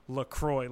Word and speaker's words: LaCroix: So renamed LaCroix, but spelled with the X like French, but then pronounced LaCroix, like LaCroix River in LaCroix, Wisconsin.